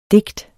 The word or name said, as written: DIGTENES